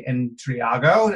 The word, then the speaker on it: Intriago: Today we have Leslie Intriago.